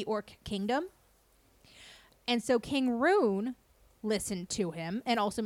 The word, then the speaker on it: Rhun: King Rune, which is spelled R-H-U-N.